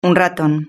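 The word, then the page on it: un raton: Spanish (Spain)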